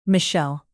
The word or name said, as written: michelle